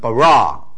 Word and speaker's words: bara: 把它分成啊三个音节，bereshith 就是一个 preposition in reshith beginning 啊 bereshith，好，大声一点 bereshith，第二个字很有用，叫 bara， he create 是过去时态 he create 啊，所以很清楚，两个都是第一个是 open syllable，第二个是 close syllable 啊。但是因为它重音在后面 bara，所以它还是用一个长音节 bara 啊。